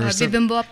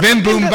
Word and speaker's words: Bibimbap: Simbumbap or Bibimbap? – Oh, Bibimbap.